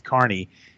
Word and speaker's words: Kearney: I have to correct you on that. It's Kearney. – I say Kearney. Sorry, that's where I come from. That's my slang. – No, that is how it's spelled. I'll give you that, but it is pronounced Kearney.